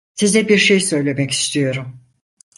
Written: istiyorum

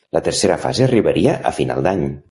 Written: tercera

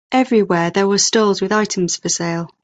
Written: stalls

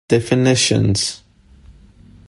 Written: definitions

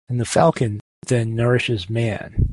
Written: nourishes

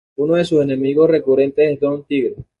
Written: recurrentes

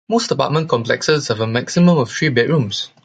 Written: complexes

